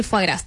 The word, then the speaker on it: foie gras: Brasil, acá tenemos una, Brasil prohibió el foie gras, que lo tuve que googlear, como se decía, porque se escribe foie gras. – Esa es la forma correcta, entonces. – Sí, díganlo foie.